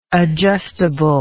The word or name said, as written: adjustably